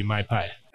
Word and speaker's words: Mai Pai: So she said, well, it's pizza and a tiki bar, so it's got to be My Pie. – And it's spelled M-A-I-P-A-I.